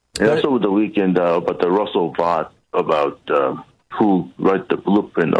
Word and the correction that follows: Vought: Yeah, it's pronounced vote, but yes, absolutely.